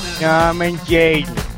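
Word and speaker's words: Norman Jayden: Say it right, dude. It's Norman. – Norman Jaden.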